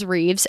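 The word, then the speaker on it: Rives: There's just like, I know that my name is Reeves and it's spelled R-I-V-E-S. I know that's an odd spelling of the way you pronounce it, but it just really irks me when people spell my name wrong.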